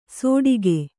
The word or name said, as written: ಸೋಟಿಗೆ